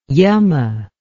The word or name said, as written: yama